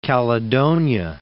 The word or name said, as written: Caledonia